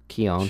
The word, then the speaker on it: Keoghan: I mean, I could see easily three nominations between Barry Keoghan. Was that how you say it? – Keown, sorry. It's not how it's written.